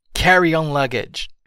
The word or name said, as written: carry-on luggage